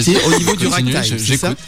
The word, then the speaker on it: ragtime: C'est le ragtime, pour prononcer à l'anglais.